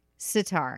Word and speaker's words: sitar: Sitar. Okay. Not sit. I had a weird, I put the emphasis on the wrong syllable.